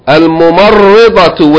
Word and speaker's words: الممرضة: الممرضة with شدة. This word is hard to pronounce. It has a شدة on the ra. ممرضة. الممرضة. ضاد. ضاد. The letter ضاد, not دال.